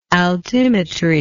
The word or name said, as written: altimetrically